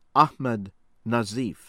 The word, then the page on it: Ahmed Nazif: American English